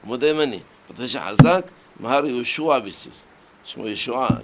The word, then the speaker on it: יהושע: כי האשכנזים לא מבטאים את העין. הם יגידו, יהושע, נשמע באוזנם כמו אותו האיש. הרי את העין לא מבטאים, יהושע, יהושע, יהושע זה מזכיר את אותו האיש.